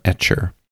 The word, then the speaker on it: Etchr: So I ordered, and I've talked about this in a previous episode, I ordered some sketchbooks from Etcher. And it's spelled E-T-C-H-R.